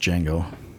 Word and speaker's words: Django: Now, Django is obviously much more just brutal. – The D is silent. It's just Django.